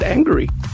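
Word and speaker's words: Angourie: Her name is A-N-G-O-U-R-I-E. I think you pronounce it angry.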